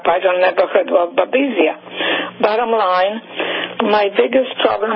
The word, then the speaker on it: Babesia: I'll spell it B-A-B-E-S-I-A. – Yeah, Babesia.